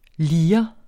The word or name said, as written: lirens